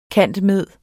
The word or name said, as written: cand.med.